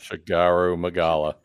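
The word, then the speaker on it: Shagaru Magala: Like, I really like I like the one that we keep doing, Shala Magala. Did I say that right? – Shigaru Magala.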